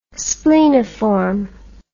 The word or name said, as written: spleniform